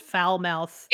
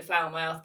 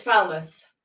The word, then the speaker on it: Falmouth: How is your college pronounced? – I mean, when we were drunk, we did call it Falmouth. But see, you know, we were, you know, it's a student joke, but it's Falmouth.